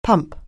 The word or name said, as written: pump